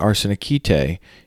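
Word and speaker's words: arsenikete (ἀρσενοκοῖται): You'll also learn what arsenikete, using the modern Greek pronunciation here, means in 1 Corinthians 6:9, a key battleground text for discussions of biblical sexuality.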